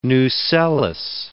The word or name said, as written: nucellus